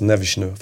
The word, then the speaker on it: Neves: Man tänker väl framförallt på Neves och som tydligen uttalas Nevishnu, fick man lära sig i helgen. Det var, väldigt, var mycket portugisiskt uttal på honom där.